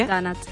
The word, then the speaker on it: donut: Però una cosa, hablando correctamente, ¿sería donut o donut? ¿Cómo sería en inglés? Porque a lo mejor hay que pronunciar donut. – Sí, sí, sí, en realidad sí, donut.